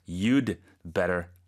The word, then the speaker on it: you'd better: 그렇죠. 쓸 때는 you 하고 apostrophe 하고 d까지 붙여서 you'd better. 근데 발음할 때는 그 d 부분이 거의 발음이 안 들릴 정도로 you'd better, you'd better 이렇게 된다는 거네요.